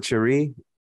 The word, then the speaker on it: Cherie: Is that how you pronounce it? Cherie? Yeah, you know, like okay. in French, like the SH. H is the sh.